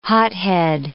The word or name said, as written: hothead